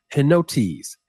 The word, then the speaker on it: henotes: Now the word unity in those two verses, okay, is the Greek feminine noun henotes. Henotes, that's spelled H-E-N-O-T-E-S, henotes, which translated means unanimity or agreement, okay?